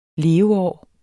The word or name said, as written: leveår